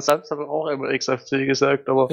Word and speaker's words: XFCE: irgendwann davor vorbeikamen und meinten so, ja, hey, seid ihr nicht die von X-Face? Nee, es ist XFCE.